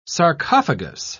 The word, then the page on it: sarcophagous: British English